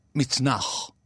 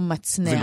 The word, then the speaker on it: מצנח: אז אני שומע לפעמים מצנח. – ולא מצנח. – ולא מצנח. מקלע. ולא מקלע.